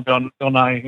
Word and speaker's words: Nighy: His stepfather, who, you know, that whole thing with, what is it, Bill Nighy? Isn't that how you say his name? – Yeah, Bill Nighy, yeah.